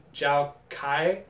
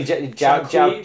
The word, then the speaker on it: Zhou Qi: You mean Zhou Kai? Kwai? – You mean Zhou Qi. Zhou Qi. – His name is... Zhou Qi. – It's actually pronounced.